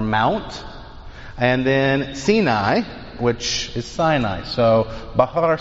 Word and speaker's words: Sinai: And they read Bahar Sinai. All right. In English, we would pronounce that Sinai, but in Hebrew, it's pronounced Sinai. So Bahar Sinai.